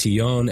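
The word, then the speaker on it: tignon: It's pronounced Tigon, and it's Tigon, and that's the traditional head wrap.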